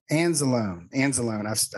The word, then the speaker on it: Anzalone: Anzalone.